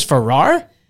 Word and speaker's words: Farrar: Yeah, it's Farrar. So, you know, the ending scene of A Christmas Story when the hound dogs eat the turkey and they go to the Chinese restaurant and they're standing around going, Farrar. That's how you pronounce my name.